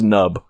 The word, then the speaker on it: P-Nub: P-N-U-B. I think it's just Nub. – Nub. – Mick and Nub. Nub. We'll say Nub. – Right. – The P is silent.